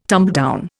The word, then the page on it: tumbledown: Indian